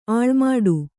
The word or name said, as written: ಅಲೆಮಾಡು